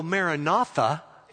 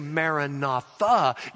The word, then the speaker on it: Maranatha: If you go Maranatha, you're saying, Lord Jesus, come. Lord Jesus, come. It's a prayer. But if you say Maranatha, you put the accent on the last syllable. You're not saying, Lord Jesus, come. You're saying, the Lord comes.